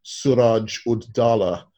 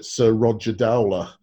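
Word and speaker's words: Siraj ud-Daulah: In Imperial India, there was a very prominent sepoy called Suraj Ud Dowlah, and the British pronounced it Sir Roger Dowler.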